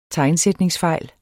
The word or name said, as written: tegnsætningsfejl